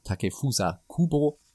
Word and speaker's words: Takefusa Kubo: Ja, mit Takefuso, äh, Takefusa Kubo wie halt ausgesprochen wird, äh, kommt tatsächlich nochmal ein aufregender Spieler, der ist ja auch nach wie vor erst 19.